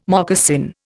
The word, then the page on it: moccasin: Indian